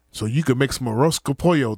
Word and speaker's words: arroz con pollo: That's arroz con pollo.